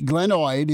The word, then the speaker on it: glenoid: He's got a max guaranteed deal, but an MRI performed Monday revealed that Watson had a displaced fracture on the glenoid, his right shoulder. Explain that to us and correct the pronunciation. – Yeah, you bet. Ah, nice work. Glenoid is perfect.